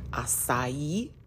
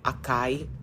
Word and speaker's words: açaí: We are the capital of the world for açaí. That's how it's pronounced. If you said it acai, that's okay. I won't kill you. It's okay. You can butcher it.